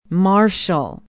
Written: marshall

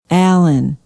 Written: allen

